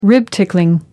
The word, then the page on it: rib tickling: American English